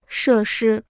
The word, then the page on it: 设施: shè shī